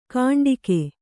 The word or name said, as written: ಕಾಂಡಿಕೆ